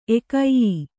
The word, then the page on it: ಎಕೈಯಿ: Kannada